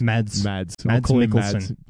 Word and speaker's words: Mads Mikkelsen: Mads Mikkelsen.